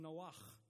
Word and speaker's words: Noach: Like Noah is actually pronounced Noach in the Hebrew.